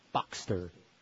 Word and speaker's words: Boxster: Boxster, am I saying that right? – Boxster.